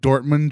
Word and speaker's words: Dortmund: Dortmund, Germany.